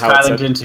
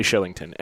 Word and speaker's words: Kylington: It's definitely Shillington. Anyway. – You'd think it would be an X, then, not a K. – But it's Swedish. – I guess. – That's how they pronounce it.